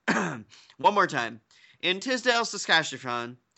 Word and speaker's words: Saskatchewan: Tisdale, Saskatchewan? – Yeah, that's how you do it. I believe that is phonetically all right. – Correct.